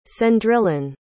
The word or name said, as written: cendrillon